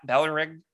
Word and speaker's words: Balrog: is he straps a jetpack to young Boba and sends him through the Ballyreg. Ba- Ballyreg you kind of like swallow the Y, right? Balrog. – Balrog. I don't know. Roll your R.